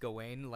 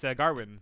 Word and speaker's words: Gawain: Did you know Gawain wasn't actually pronounced Gawain like I thought it was? – It's Garwin. – Yeah, it's Garwin.